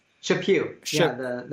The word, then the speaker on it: Chaput: Also, Charles, is it Chaput? Is that how you pronounce it? – Chaput, the retired Archbishop of Philadelphia.